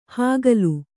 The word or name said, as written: ಹಕಲು